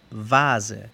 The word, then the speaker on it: Vase: V-A-T-E-R, Fata. O la misma pronunciación que la W, que sería como la V en inglés. Florero se dice vase, V-A-S-E, vase.